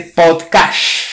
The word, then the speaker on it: podcast: fija y da esplendor de podcast, con acento en la primera sílaba, en la pop.